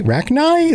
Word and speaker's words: Rachni: Rachni? Is that the proper pronunciation? Rachni? Rachni? – Rachni. – Yeah.